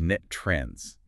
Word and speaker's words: NTREIS: And just a quick note for you listening, the industry pronounces that acronym NTREIS as Net Trends.